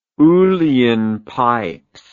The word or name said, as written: uillean pipes